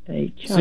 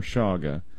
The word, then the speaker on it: chaga: Oh, I probably have mispronounced every herb you can think of, but it's called chaga or shaga.